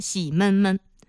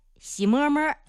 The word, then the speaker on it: 洗摸摸: 朋友听节目或者说去山东旅过游，如果你是自驾去的啊，你说特别脏了洗洗车吧，它不叫洗车，它也不叫洗车行什么洗车店，你去山东旅游就会发现这个大街上写着仨字啊，洗闷闷，洗车的洗，闷热的闷，洗闷闷，什么意思？就是洗车。说在山东胶东地区的方言，这个正确读音应该是洗摸摸。哎，就是车开着摸摸来，洗摸摸。